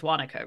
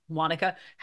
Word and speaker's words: Wanaka: So you went down for Wanaka. Did I say it right? It's Wanaka, right? – Yes. Yeah, Wanaka.